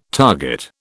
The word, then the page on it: target: British English